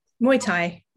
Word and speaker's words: Muay Thai: Muay Thai. Um, do I say that right? – Muay Thai. – Muay Thai. – Muay Thai. – Muay Thai. Sorry, Muay Thai.